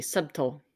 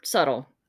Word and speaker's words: subtle: There was a B in their subtle. – Yeah. Yeah. – They pronounced the B, subtle. – Yeah. – Other people were subtle.